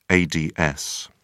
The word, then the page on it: ads: British English